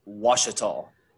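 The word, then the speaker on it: Ouachita: It is Wachita. – Okay. – Pronounce it like it's a W.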